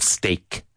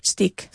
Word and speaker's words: steak: What's the common mistake in the pronunciation of steak in Spanish? – Stick. – Stick. Yeah. I'd like a stick, please.